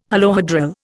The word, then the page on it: holohedral: Indian